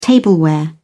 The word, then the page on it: tableware: British English